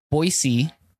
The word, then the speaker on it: Boise: You and I went to a conference up in Boise. – Mm-hmm. That's how you pronounce it, Boise.